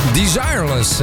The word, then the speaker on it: Desireless: Desireless, hè? Ja, zo spreek je dat uit officieel.